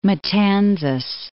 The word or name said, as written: Matanzas